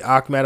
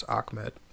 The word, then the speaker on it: Ahmed: His name is actually Ahmed. I know it's spelled Ahmed, but all the game announcers have, and everyone I've heard announce his name, I guess because of the pronunciation, it's Ahmed, but just word.